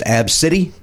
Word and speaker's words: Abcde: There's a young girl who was, they were getting on Southwest Airlines, and the young girl's name is ABCDE, pronounced Ab City.